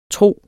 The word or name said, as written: tro